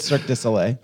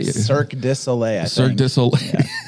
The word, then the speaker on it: Cirque du Soleil: How do you say this? – Cirque du Soleil. – Is it Cirque du Soleil? – Cirque du Soleil.